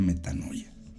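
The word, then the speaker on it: metanoia (μετάνοια): llegar a un arrepentimiento y en griego la palabra arrepentimiento se pronuncia metanoia.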